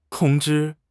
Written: tong1 zhi1

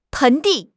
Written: 盆地

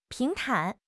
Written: 平坦

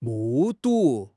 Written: 目睹

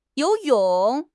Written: you2 yong3

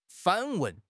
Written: 反问

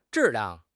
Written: zhi4 liang4

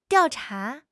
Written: diao4 cha2

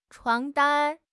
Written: 床单

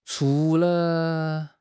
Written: chu2 le5

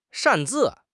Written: 擅自

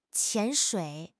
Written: 潜水

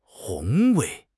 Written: 宏伟

